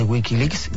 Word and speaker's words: Wikileaks: Regresamos, los papeles, tenemos a Miguel Pedrero aquí ya con todos los datos secretos de Wikileaks. ¿Leaks se dice? – Sí, Wikileaks. – Lo digo bien.